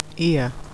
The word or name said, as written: ear